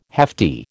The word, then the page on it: hefty: American English